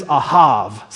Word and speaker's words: ahav: That word in the Hebrew is ahav. Say that word with me. He's got a V sound at the end.